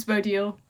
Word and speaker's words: Bodil: um, my full name's Bodil, but most people just call me Bo.